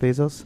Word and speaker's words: Bezos: That's how you say his name? Jeff Bezos? – Yeah.